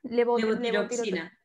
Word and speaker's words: Levotiroxina: Hemos hablado de la levotirotoxina, ¿lo he dicho bien? – Levotiroxina. – Levotiroxina, casi.